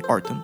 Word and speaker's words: Horton: Est-ce que je le prononce bien? Horton. – Comme Tim Horton.